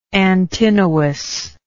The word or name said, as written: Antinous